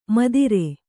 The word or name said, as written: ಮದಿರೆ